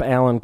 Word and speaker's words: Alan: My name is Jacob Alan Kuban. You can find me online at Jacob Alan Kuban. That's A-L-A-N spelled like anal dyslexically.